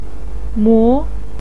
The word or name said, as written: mo2